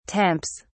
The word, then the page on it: temps: British English